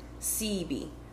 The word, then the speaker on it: Sebi: Sebi. You pronounce the E.